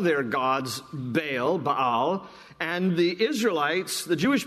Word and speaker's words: Baal: So God, through Zephaniah, first addresses the problem of idolatry in the land, and he points out the worship of Baal there in verse 4, more properly pronounced Baal.